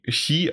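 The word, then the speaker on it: Chi (χ): Das X, das wird Xi, Chi ausgesprochen und deswegen heißt das Kingdom Hearts Xi Backcover.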